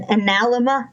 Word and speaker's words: analemma: I'm going to botch the pronunciation, but an analemma, I think is how you say it. I think it's A-N-A-L-E-M-M-A.